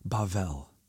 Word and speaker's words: Bavel: Depending on where you are from, you've probably heard it called Babel or Babel. For reasons that will become clear as the story progresses, I'm going to use the Hebrew pronunciation Bavel.